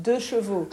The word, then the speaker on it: deux chevaux: Deux chevaux, right? – Deux chevaux.